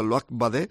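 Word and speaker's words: Loïc Badé: El Luac, me decía Irene Ramos, que habla muy bien en francés, que se pronuncia Luac Badé, 22 años, central francés del Stade de Reims, donde lo compraron y donde no jugó, y lo cedieron a los ingleses.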